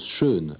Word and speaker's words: Sean: On prononce Sean à l'irlandaise et Connery comme en français.